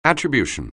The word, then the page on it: attribution: anglais